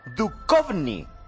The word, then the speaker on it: Duchovny: Sí, o sea, que la Che allí es K. Duchovny.